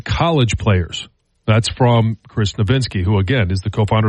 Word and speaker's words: Nowinski: And now there is a, you know, concussion expert, co-founder of the Boston University CTE Center, which is, Chris Nowinski, as I think how you say it, is Nowinski? – I think it's Nowinski. – Nowinski, okay. The jury is out on whether the guardian cap is helping NFL players.